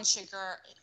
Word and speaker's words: Chigurh: Right, like Anton Chigurh.